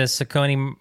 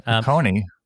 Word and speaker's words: Saucony: Saucony? Who are you? – Saucony. My bad.